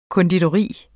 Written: konditori

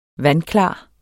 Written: vandklar

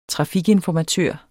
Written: trafikinformatør

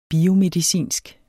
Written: biomedicinsk

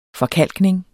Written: forkalkning

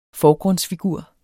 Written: FORGRUNDSFIGUR

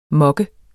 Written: mokke

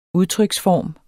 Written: udtryksform

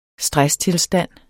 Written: stresstilstand

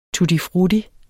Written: tutti

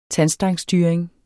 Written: tandstangsstyring

